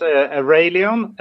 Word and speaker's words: Arelion: You have this new visual identity name called Arelion. Tell me a little bit more about that process, and more importantly, am I even pronouncing the name correctly? – Yeah, you're actually really, really close, I would say.